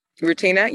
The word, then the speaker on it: Rutina: Oh, my answer is Rustina Wesley. I believe she's in Queen Sugar. She was on an episode of Arrow. That's where I first saw her. Hopefully I'm saying her name correctly. Okay. – Rutina, yeah.